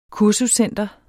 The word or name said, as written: kursuscenter